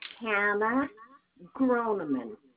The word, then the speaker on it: Groneman: Hannah Groneman.